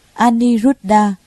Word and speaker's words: Aniruddha: Ngài Anau Lâu Đà, Ấn Độ đọc là Aniruddha, chữ Hán là Vô Bần.